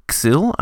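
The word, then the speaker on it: Xill: The first monster on today's list is the Xyl. I've decided that's how I'm going to pronounce it. It's X-I-L-L, and it was created by Brian Asbury.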